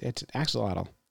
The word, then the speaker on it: axolotl: It used to be held by the Mexican, an axolotl? – No, that's axolotl. – Axolotl. – Yeah. – Phonetically spelled.